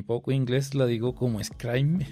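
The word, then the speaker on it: Scribeme: Pronuncia Scrimey.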